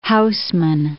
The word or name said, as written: Housman